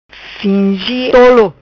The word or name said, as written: Fingi Tolo